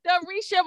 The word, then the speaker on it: Durecia: Durecia Moore is an international speaker and marketing strategist, leading in the marketing and advertising service line at ABCD and Company.